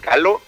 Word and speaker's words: Gallo: Desde la Ciudad de México surge esta agrupación que se llama Gallo. ¿Sí lo pronuncio bien? – Sí, ahora sí que como ustedes gusten, está chido. Nosotros le decimos Galo, pues como para hacer ahí tantito diferencia, ¿no?